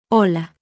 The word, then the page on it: h: letter; Spanish alphabet